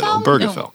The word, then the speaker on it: Obergefell: Everybody mispronounces that, but you nailed it. Obergefell.